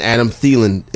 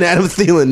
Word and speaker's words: Adam Thielen: As far as the Vikings go, I think you can confidently start both Stephon Diggs and Adam Thielen. Did I say that right, Greg? – You did, yes. For the first time. – And Adam Thielen this week.